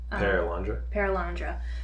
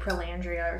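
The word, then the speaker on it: Perelandra: Paralandra. – I always want to say like Prolandria or something. Paralandra.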